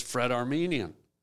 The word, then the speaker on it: Fred Armisen: So just Fred Armisen.